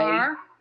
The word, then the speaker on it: bear: In Robbinsville, the word B-E-A-R, bear, yeah, of course, is never pronounced that way. – Bar. – It's a bar, B-A-R.